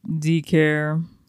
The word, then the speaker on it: decare: Last word, decare. It's spelled D-E-C-A-R-E. – Decare. – Sounds like my like, main word. – It really does. – It just sounds like you decrease care.